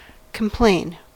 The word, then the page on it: complain: American English